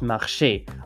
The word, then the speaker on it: marcher: And why, for instance, a common one I get is why the word marche, M-A-R-C-H-E, is pronounced marche, but the word marcher, M-A-R-C-H-E with an accent aigu, is pronounced marché.